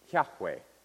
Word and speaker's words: Yahweh: It's a placeholder instead of the real name, which I believe should be pronounced Yahweh. That's one of the ways that pronounce it. So what happened over time is people learned that the A-O-I vowels go with the Yod, He, Vav, He, the Yah.